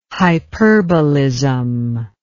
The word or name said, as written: hyperbolism